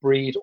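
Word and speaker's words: Brigid: And the Catholic Saint Bridget is also very much associated with an Irish pagan goddess, Breed, or, well, yeah, pronounced Breed or Bridget.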